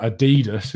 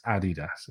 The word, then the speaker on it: Adidas: Yeah, and as I say that, I'm really not Adidas is what some people... Oh, God, it's Adidas, guys. It's Adidas is how it's pronounced.